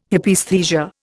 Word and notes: Hypaesthesia: Indian

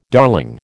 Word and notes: darling: American English

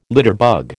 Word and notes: litterbug: American English

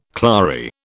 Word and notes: clary: British English

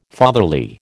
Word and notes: fatherly: American English

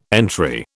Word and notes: entry: British English

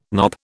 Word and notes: knop: British English

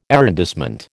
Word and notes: arrondissement: American English

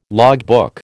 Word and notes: logbook: American English

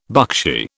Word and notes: buckshee: British English

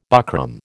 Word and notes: buckram: American English

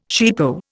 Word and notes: cheapo: Indian

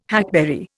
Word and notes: hackberry: Indian

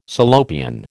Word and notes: Salopian: American English

